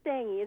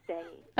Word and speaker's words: Stange: We pronounce it stangy. It's German word. It's stange, meaning rod or post, but I say stangy.